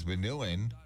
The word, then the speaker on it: vanillin: The study also found that the chemical in vanilla, known as vanillin, which rhymes with villain, but not really tied to villain, can aid in every stage of wound repair, from helping close wounds faster to restoring tissue integrity and improving skin strength.